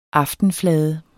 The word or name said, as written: aftenflade